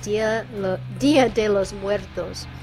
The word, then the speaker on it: Día de los Muertos: With their song Día de los Muertos. – You pronounce that perfect, almost. That's perfect to me.